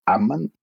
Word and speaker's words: Aman: Eh, sí, ese apellido se pronuncia con el acento en la primera A, o sea, Amán.